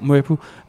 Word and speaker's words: Mwepu: Mwepu? Er det ikke, er det jo. ikke sådan, det udtales? Jo, jo Mwepu.